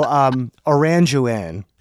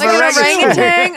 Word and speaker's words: orangutan: And also Orangewan. – Instead of orangutan.